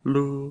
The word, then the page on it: lu: Lwaitel